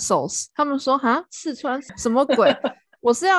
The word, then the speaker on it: 四川: 嗯，他们不知道真真正的发音是四川，他们就是四川。